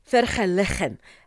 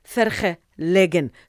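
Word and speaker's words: Vergelegen: Ah, so you see, I used to pronounce it verhelegen, only to be corrected, it's verhelegen. So from today onwards, I've got it right.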